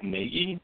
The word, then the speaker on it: Nagy: Is it Matt Nagy? Is it is the? Uh, I believe that's correct. yes.